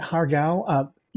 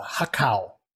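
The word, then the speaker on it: har gow: So, yeah, you know, again, Hargao, you may hear like if they're speaking Mandarin or Cantonese, it's a Hakao. They don't pronounce that G.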